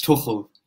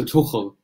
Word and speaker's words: Tuchel: So I'm Swiss, so I speak German, so it's Thomas Tuchel, like that. You got something in your throat, so Tuchel.